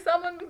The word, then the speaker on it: salmon: I'm like, damn, baby, you should make my salmon better. Which I know, guys, as you can see, English is my second language. I just learned that you don't pronounce the L.